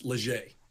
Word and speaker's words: Legge: The last name is uh, pronounced Leger.